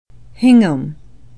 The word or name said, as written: hingham